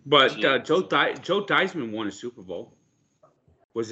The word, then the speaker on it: Theismann: When he went to Notre Dame, they suggested he change his name to change the pronunciation of his name to Theismann because it rhymed with Heisman.